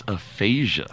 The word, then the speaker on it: aphasia: Yeah, well, perhaps the biggest story from yesterday was Bruce Willis's announcement that he is stepping away from acting amid aphasia diagnosis. Did I say that correctly? – Yes, aphasia.